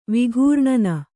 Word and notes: ವಿಘೂರ್ಣನ: vighūrṇana

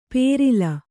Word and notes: ಪೇರಿಲ: Kannada

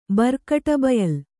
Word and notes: ಬರ್ಕಟಬಯಲ್: Kannada

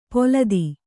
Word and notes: ಪೊಲದಿ: Kannada

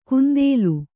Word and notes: ಕುಂದೇಲು: kundēlu; dial.

